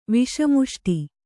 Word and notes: ವಿಷಮುಷ್ಟಿ: kannada

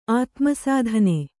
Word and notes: ಆತ್ಮಸಾಧನೆ: ātmasādhane

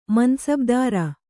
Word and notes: ಮನಸಬದಾರ: manasabadāra

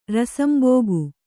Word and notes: ರಸಂಬೋಗು: Kannada

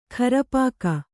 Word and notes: ಖರಪಾಕ: kharapāka

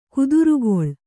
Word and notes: ಕುದುರುಗೊಳ್: kudurugoḷ

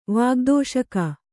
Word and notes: ವಾಗ್ದೋಷಕ: kannada

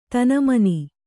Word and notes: ತನಮನಿ: Kannada

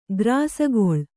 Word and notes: ಗ್ರಾಸಗೊಳ್: grāsagoḷ

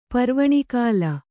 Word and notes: ಪರ್ವಣಿಕಾಲ: kannada

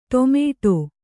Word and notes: ಟೊಮೆಟೊ: ṭomēṭo

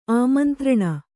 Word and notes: ಆಮಂತ್ರಣ: āmantraṇa

